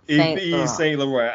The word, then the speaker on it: Yves Saint Laurent: Yves Saint Laurent.